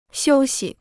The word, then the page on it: 休息: xiū xi